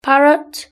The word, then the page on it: parrot: British English